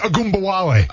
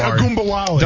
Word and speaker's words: Ogunbowale: Oh, so close. Dare Ogunbowale. – Ogunbowale. – Dare Ogunbowale. – Dare Ogunbowale. – And I'll keep on saying it because I'm saying it right.